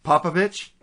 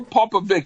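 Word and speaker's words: Popović: Popovich? – Yeah, or Popovich. It's like a Czechoslovakian name, so either pronunciation is fine.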